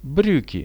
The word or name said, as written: briuki